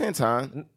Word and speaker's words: Antoine: Anthony. – No, it's Antoine.